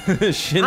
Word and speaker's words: Shinsuke: Shinsuke, I'm a little bit of a slow American.